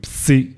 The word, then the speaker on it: psi: es que se pronuncia así, psi y omega.